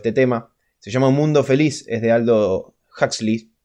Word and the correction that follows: Aldous Huxley: Aldous Huxley, perdón. – Pronuncia bien, por favor. – Aldous Huxley, se escribe A-L-D-O-S y el apellido H-U-X-L-E-Y.